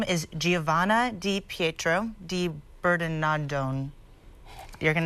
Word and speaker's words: di Bernardone: How do you pronounce that, huh? – Mr. Italian? – Di Bernardone.